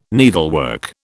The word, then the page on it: needlework: British English